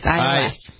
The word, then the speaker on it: iOS: How do you say, is it iOS? What is it? – It's their operating system, yes, iOS.